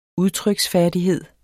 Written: udtryk

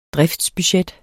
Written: driftsbudget

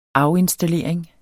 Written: afinstallering

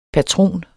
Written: patron

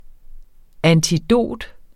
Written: antidot

